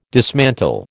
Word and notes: dismantle: American English

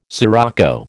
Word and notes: scirocco: American English

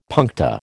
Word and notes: puncta: American English

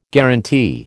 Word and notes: guarantee: American English

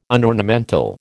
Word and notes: unornamental: American English

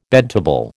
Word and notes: bedtable: American English

